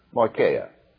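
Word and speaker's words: moicheia (μοιχεία): It is spelled M-O-I-C-H-E-I-A, moicheia.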